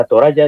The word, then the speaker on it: Toraja: Los Tana Toraja, que es lo que significa el país de los Toraja, Toraja, se escribe Toraja, pero se pronuncia Toraja, en español sería con Y.